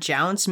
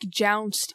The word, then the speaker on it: jounce: jolt or shake, but just the sound of the word jounce makes me feel like, oh, it's happy, but it's not. Um Yeah. I I feel like jounced is like it, I mean it rhymes with bounced.